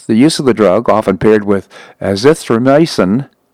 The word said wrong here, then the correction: azithromycin: I'll say that again, azithromycin, I put the emphasis on the wrong syllable there, anyhow, has not yet been proven in clinical trials to be effective against the disease.